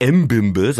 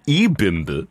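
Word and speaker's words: Ebimbe: Er heißt nicht M-Bimbe, sondern Dina E-Bimbe.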